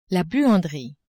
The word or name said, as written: buanderie